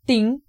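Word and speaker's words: ting (听): Y cuando vi la palabra escuchar en chino, que se pronuncia ting, descubrí que esa palabra se compone de cuatro símbolos más pequeños que cada uno tiene un significado específico.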